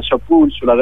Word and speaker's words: Chapulcular: Sì, diciamo, la pronuncia è più o meno quella, Chapulcular, è una specie di sostantivo che poi ha varie declinazioni, Chapulcu, Chapulcular e quant'altro.